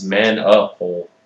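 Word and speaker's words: Manapul: You butchered the fuck out of Francis Manapal's name there. – I misspelled it. I put CES instead of CIS. – And it's Manapal. – Whatever.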